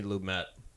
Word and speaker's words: Lumet: So, as we talk about our first viewing of the 1976 Sidney Lumet, it is Lumet, not Lumet.